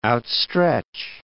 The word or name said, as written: outstretch